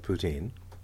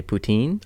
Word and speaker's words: poutine: I'm pronouncing it in English. – But you're not pronouncing it how the creators pronounce it. – That's correct. – Exactly. So it should be poutine.